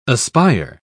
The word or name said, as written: aspire